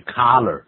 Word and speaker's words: collar: C-O-L-L-A-R. C-O-L-L-A-R. Collar. – 也就是衣领哈。在衣领下它整个发热的, 就是那个热气快爆出来, 快发火的意思啊。我们用 collar.